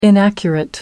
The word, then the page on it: inaccurate: American English